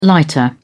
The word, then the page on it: lighter: British English